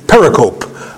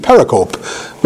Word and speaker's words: pericope: If you ran across it in a book, you'd probably pronounce it pericope. I always pronounce it pericope because that's what it looks like. P-E-R-I-C-O-P-E, pericope. But actually the correct pronunciation for all the people that teach in seminaries is a pericope.